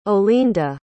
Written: Olinda

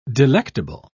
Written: delectable

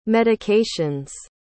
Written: medications

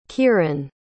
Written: keeran

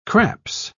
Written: craps

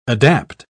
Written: adapt

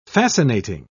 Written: fascinating

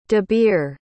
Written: dabir

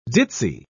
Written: ditsy

